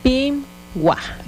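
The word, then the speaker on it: pimwáj: Pingwa.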